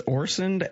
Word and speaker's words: Orsund: So it is Orson, as you see it, just the D is silent.